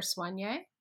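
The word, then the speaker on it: Soigné: S-O-I-G-N-E. How do you say that? – Soigne or Soigne? – Soigne.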